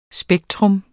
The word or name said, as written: spektrum